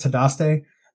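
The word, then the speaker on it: Dahteste: So in 1883, her and another woman named Tadaste, which is spelled with a D. So it's really confusing. It's D-E-H-A-S-T-E. But it's pronounced Tadaste.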